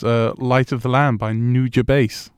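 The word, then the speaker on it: Nujabes: In my head, it sounds like new jazz bass.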